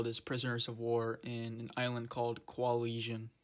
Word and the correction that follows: Kwajalein: I might have butchered the name Kwajalein, Kwajalein, but